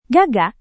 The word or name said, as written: gagak